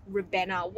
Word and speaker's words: Ribena: It's pronounced Ribena.